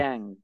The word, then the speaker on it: Wan Gang: Wan Gang.